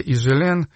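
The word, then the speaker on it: Higelin: Жак Ижелен его зовут, французская фамилия Ижелен. Она пишется, там первая буква H, Хижелен пишется, хотя буква эта не читается.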